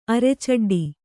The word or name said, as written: ಅರೆಚಡ್ಡಿ